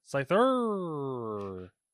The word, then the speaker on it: Scyther: Scythar is getting a new evolution. Is that Scythar? Is that how you pronounce that? Scyther? – Yeah. – Scyther is getting a new evolution called Cleavor, which is very clever of them.